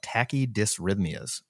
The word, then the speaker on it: tachydysrhythmias: Now, you said here, you linked a study, the iStop AFib randomized controlled trial, and you talk about tachydysrhythmias. Now, one, I want kudos for pronouncing that word correctly.